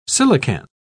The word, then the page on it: coelacanth: British English